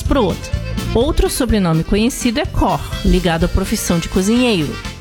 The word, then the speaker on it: Koch: Se escreve Koch, K-O-C-H, como vemos em uma rede de supermercados de Santa Catarina. Mas popularmente as pessoas falam Koch, e na verdade é Koch. Como no sobrenome, no português, falado com um O aberto, como se fosse acentuado e, quem sabe, dois H seguidos juntos bem pronunciados, com os lábios abertos em formato de O.